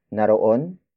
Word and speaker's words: naroon: Word for word, it's naroon, kay, Chris, ang pagkain mo. Naroon kay Chris ang pagkain mo. Naroon kay Chris ang pagkain mo. The first word is naroon. Naroon. Naroon. Naroon is spelled as N-A-R-O-O-N.